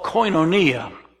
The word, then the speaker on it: koinonia: The word fellowship, it comes from the translation of a word called koinonia. Could you pronounce that for me? Koinonia, from the word koinos. Koinos, which means common.